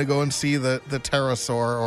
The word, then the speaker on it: pterosaur: That's a pterosaur. The P is silent.